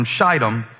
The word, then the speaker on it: Shittim: It says, early in the morning, Joshua and all the Israelites set out from Shittim, that's a long I.